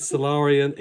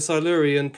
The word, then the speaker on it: Silurian: The conclusion to this year's Solarian extravaganza. – Solarian? What's a Solarian? Is that a Silurian that's been on a bed for too long? – No. Oh. It's a Silurian pronounced correctly.